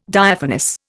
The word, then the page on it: Diaphanous: Indian